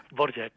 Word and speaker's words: Dvořák: I com es pronunciava? – Dvořák. – Dvořák, eh? – De fet, hi ha moltes diverses maneres i versions per pronunciar aquest nom. Dvořák és la que està més extensa, sí.